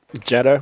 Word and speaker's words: Jeddah: The greatest Royal Rumble event happened in Saudi Arabia, in Jada, is that how you say it? – Jeddah, whatever. – Jeddah, Saudi Arabia. Something. That's all you got. – Yeah, exactly. – It kind of sounds like Cheddar.